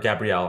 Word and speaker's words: Gabrielle: And we mentioned Tati Gabriel. I hope I'm saying her name right. Or Gabrielle, I'm sorry.